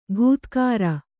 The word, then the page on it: ಘೂತ್ಕಾರ: ghūtkāra